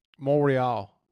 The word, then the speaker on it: Montreal: Montreal. It's called Montreal.